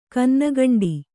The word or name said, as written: ಕನ್ನಕಂಡಿ